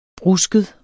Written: brusket

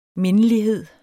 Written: mindelighed

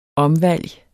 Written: omvalg

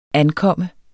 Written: ankomme

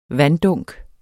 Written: vanddunk

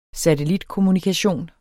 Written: satellitkommunikation

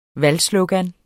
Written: valgslogan

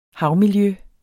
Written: havmiljø

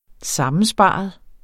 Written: sammensparet

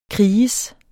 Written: kriges

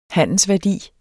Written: handler

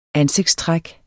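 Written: ansigtstræk

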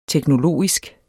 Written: teknologisk

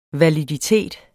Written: validitet

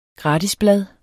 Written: gratisblad

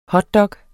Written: hotdog

